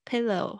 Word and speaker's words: pillow: pillow，还有这个 o w 是发 o，所以它一定要发的比较完整一点。所以刚开始你在学发音的时候，尽量嘴型夸张一点，pillow，pillow 啊，因为这样子你在外面的时候，当你放松的时候，你才会发出正确的声音。